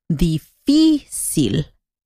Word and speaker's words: difícil: Y el acento o la sílaba que pronuncias más fuerte es la primera. Difficult. En español, ahí viene el acento. En la segunda sílaba. Entonces decimos difícil.